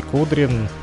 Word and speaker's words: Кудрин: А, так вот, совсем недавно российский либеральный политик, зовут его Алексей Кудрин, если я правильно, конечно, поставил ударение, потому как э, не особо знаком с ним. Да, правильно, посмотрел вот в гугле.